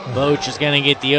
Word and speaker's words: Boach: Did I say that right? That's how you said it last time, right? – That's how I said it last time, yes. – I like it. Boach.